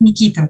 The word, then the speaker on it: Никита: Это же имя как бы оно во всех фильмах, в песне везде как бы, на английском языке да, звучит как «Никита». То есть это в русском уже переводе ударение ставится на последний слог.